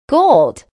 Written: gold

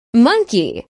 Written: monkey

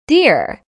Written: deer

